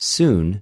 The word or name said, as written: συν